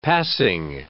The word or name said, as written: passing